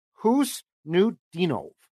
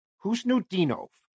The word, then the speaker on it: Khusnutdinov: His name is spelled K H U S N U T D I N O V. And I thought to myself, I got to find that pronunciation. I got no clue. According to uh, Gord Miller, who calls the World Juniors for TSN, it's who's it's who's Nudinov, who's Nudinov, Marat who's Nudinov, who is supposed to be a big time player and he's very good.